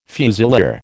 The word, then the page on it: fusilier: American English